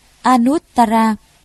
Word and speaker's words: Anuttara: Chữ A Nậu Đa La nguyên âm Ấn Độ đọc là Anuttara, dịch ra chữ Hán là vô thượng, nghĩa là trên hết.